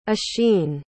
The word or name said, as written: oisin